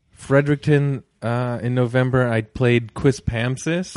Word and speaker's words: Quispamsis: You said it right. You just put the accent on the Pam. I think that's cool. Quispamsis.